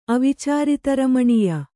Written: ಅವಿಚಾರಿತರಮಣೀಯ

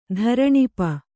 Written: ಧರಣಿಪ

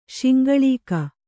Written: ಶಿಂಗಳೀಕ

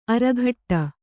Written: ಅರಘಟ್ಟ

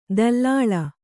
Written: ದಲ್ಲಾಳ

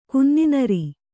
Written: ಕುನ್ನಿನರಿ

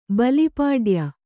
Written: ಬಲಿಪಾಡ್ಯ

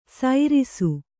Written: ಸೈರಿಸು

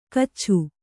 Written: ಗಚ್ಛ

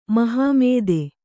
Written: ಮಹಾಮೇದೆ

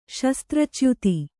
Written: ಶಸ್ತ್ರಚ್ಯುತಿ

